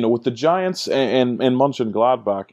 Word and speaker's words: Mönchengladbach: I'm going with the New York Giants and Borussia Mönchengladbach. Staying in your current country of Germany, staying away from the Premier League this week. – Top pronunciation there, by the way. Top pronunciation.